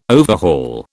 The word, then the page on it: overhaul: British English